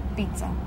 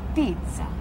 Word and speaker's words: pizza: We pronounce everything. If there is two consonants, we pronounce them both. Not pizza. It's pizza, bruschetta, bella.